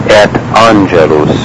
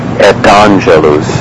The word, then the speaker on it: et angelus: So the correct pronunciation would be something like et angelus. However, you do hear people saying incorrectly something like et angelus, so it actually becomes almost like E break, T-A-N-G-E-L-U-S. That is not something that should be done.